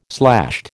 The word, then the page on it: slashed: American English